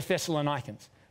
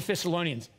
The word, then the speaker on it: Thessalonians: Now the Bereans react differently to the Thessalonians. To the Thessalonians. It's actually, I said it right the second time. The first time isn't a word. Please never say that again in public society.